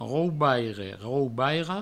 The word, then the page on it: raubaire raubaira: Provençal; raubaire raubairo